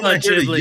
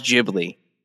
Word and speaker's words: Ghibli: I've always thought Ghibli. Yuck your yum, as they say. – No, but I'm going to course correct for the rest of my life, Justin. I'm going to pronounce it. You taught me it's not Cavender like Avengers. It's Cavender. And like now Lavender. it's Ghibli. It's yes. I'm gonna say it right.